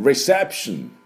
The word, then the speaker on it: reception: リセプションで、最後ですね、ションになりますので、リセプションではありません。リセプション、ね、これも気をつけてください。では私の後について発音いきましょう。 Reception, reception。